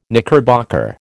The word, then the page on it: knickerbocker: American English